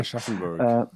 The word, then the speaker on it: Aschaffenburg: played in Aschaffenburg, if I pronounce it correctly. – Aschaffenburg. – That's the one.